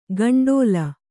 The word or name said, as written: ಕಂಡೋಲ